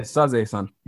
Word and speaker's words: Sazae-san: Is it Detective Conan or is it Sazae-san? Did I pronounce that? Did I pronounce that right? – Yeah, Sazae-san. Yeah, that's fine.